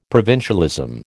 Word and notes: provincialism: American English

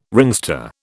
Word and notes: ringster: British English

